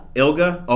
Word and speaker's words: ILGA: For those of you who don't know what ILJA is, well, if you've been watching this show, you probably have learned what ILJA is. ILGA.gov. – Yes, we call it ILGA. – ILGA? Okay. – Yeah, hard G.